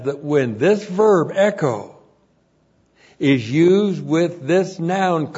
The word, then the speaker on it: echo: E-C-H long O, echo. Now, literally, echo means to have and to hold or to possess.